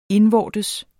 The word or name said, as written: indvortes